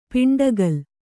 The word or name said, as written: ಪಿಂಡಗಲ್